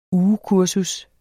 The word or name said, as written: ugekursus